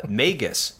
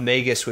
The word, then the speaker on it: Magus: So like if it is Magi, so the that's a plural of Magus, M-A-G-U-S, which I think is pronounced with a hard G.